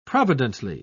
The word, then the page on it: providently: British English